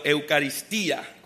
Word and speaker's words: Eucaristía: La primera parte se llamaba la Eucaristía, como habíamos dicho, o el nombre o el significado, Eucaristía, con acento en la I.